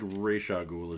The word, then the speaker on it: Ra's al Ghul: Ra's al Ghul, Ra's al Ghul. – Yeah, I think Ra's al Ghul is generally how most people pronounce it, but I don't think anybody's going to chide you too much for pronunciation on that one.